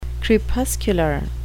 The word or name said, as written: crepuscular